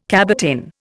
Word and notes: cabotin: Indian

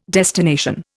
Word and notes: destination: Indian

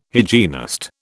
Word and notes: hygienist: British English

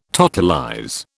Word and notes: totalize: British English

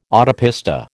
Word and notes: autopista: American English

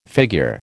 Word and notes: figure: American English

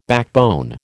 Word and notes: backbone: American English

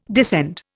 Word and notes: descend: Indian

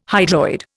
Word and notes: hydroid: Indian

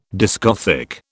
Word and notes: discothèque: British English

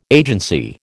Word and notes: agency: American English

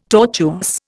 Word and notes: tortuous: Indian

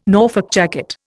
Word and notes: Norfolk jacket: Indian

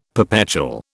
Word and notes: perpetual: British English

